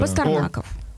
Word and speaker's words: Пастернаков: Вот очень похожая фамилия Юрия на фамилию Пастернака, но тем не менее я вынуждена уточнить, куда же ударение падает. Юрий, Николай, во-первых, здравствуйте. – Юрий Пастернаков, так, да. Пастернаков.